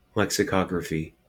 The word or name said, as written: lexicography